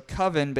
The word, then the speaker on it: Coven: So Coven is spelled C-O-V-E-N, which we know as Coven. – Mm-hmm. – So Coven, but he pronounced it Coven.